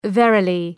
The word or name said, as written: verily